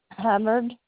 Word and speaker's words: Hubbard: You said Carla Herbert. – Hubbard, Carla with a K.